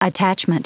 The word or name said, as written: attachment